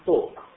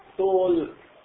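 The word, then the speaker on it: talk: يعني حتى مثلا بعض العلماء بيقولوا مثلا توك، كلمة توك باللغة الإنجليزية لاحظ الحرف اللام ما بن لا يلفظ، طب ليش لا يلفظ وبيكتبوه؟ ليش؟ في قصة في سر، لو تلفظه ايش بصير؟ تول هم مش عندهم تشكيل، احنا في عندنا تشكيل، لو تشكله بصير طلقة من الطلاقة وطلاقة اللسان والطلق.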